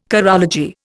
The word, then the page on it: chorology: Indian